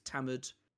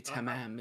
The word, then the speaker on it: Tamam: which is Tamad Shud. – Tamad? Do you mean Tamad? Which is spelled. It's Tamam. – Tamam Shud. – So it's supposed to be Tamam, isn't it?